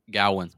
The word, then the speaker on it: Gawain: I know this was when, in the movie, Garwin, apparently, is his name. – Gowan. – It's spelled Gawain.